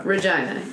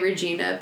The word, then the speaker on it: Regina: Regina, yes. – Slightly unfortunately named, but Regina is the capital of Saskatchewan. That's how it's pronounced. Some people say Regina, but it's not.